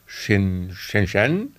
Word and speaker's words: Shenzhen: Also Shenzhen, das habe ich da gelernt. So ähnlich wahrscheinlich wird es ausgesprochen. Shenzhen sagen hier natürlich die meisten.